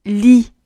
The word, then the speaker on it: lit: 第二个词 li 的关键之处也是一样的，li，一，li。在发音的时候，尽量感受到嘴型紧张的感觉。